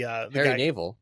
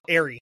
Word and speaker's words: Airey: So the guy's name was Airy Nave. Was the Harry Naval? – No, Airy. A-I-R-E-Y. – His parents couldn't say Harry. – Oh, that's how they pronounce it anyway.